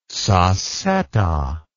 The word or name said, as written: sassetta